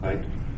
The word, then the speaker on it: Haidt: Haidt.